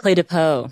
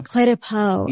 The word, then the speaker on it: Clé de Peau: Is it Clue de, you know what I'm talking about? – Clé de Peau. – Clé de Peau. – Yes. – Okay. – I believe that's how it's pronounced.